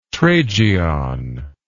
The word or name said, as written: tragion